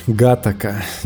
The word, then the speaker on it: Гатака: Короче, Гатака. Я даже проверил на, на Википедии ударение на первую А.